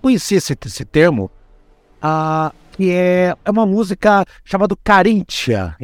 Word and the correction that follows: Caríntia: Eu falei errado, é uma música infantil Caríntia, infantil Caríntia.